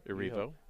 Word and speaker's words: Erivo: Erivo.